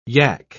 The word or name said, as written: yak